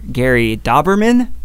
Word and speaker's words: Dauberman: And it's the It writer, Gary Dauberman. Is that is that how you pronounce that name? Sounds right to me.